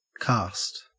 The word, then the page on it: karst: Australian English